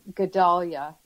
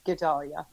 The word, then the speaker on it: Gedalia: Okay, it's um, Gedalia is okay. the first name. Gedalia. It's just how it looks then.